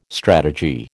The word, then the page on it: strategy: American English